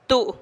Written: tuu'

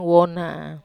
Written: wona

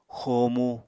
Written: xomu

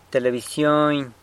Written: telebisyony

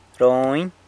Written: Rony

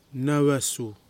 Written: Nawasu

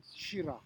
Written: shira

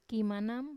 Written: kimanam

